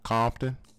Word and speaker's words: Compton: Compton? – Yes, Compton.